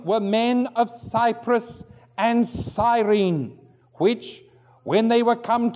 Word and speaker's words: Cyrene: in Cyrene, or Cyrene as some people pronounce it.